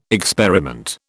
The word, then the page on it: experiment: British English